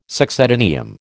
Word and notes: succedaneum: American English

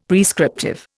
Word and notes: prescriptive: Indian

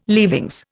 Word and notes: leavings: Indian

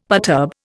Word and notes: perturb: Indian

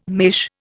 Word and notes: mesh: Indian

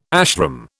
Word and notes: ashram: British English

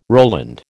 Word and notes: Roland: American English

